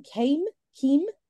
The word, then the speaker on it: Kaem: So it's called it's spelled K-A-E-M. Keem. It's called the Keem Big Daddy. – Hang on a minute. K-A-E-M.